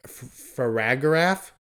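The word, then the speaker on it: Farigiraf: There is they pronounce it in the trailer, Faragraph, which is the evolved form of Girafarig.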